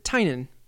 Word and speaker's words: Tynion: His name is pronounced Tynan. – Huh. – He answered that on Twitter. And I just, because, yeah, I'd always been saying Tynan? Tynion. Yep. – I've been saying Tynion, Tynan too.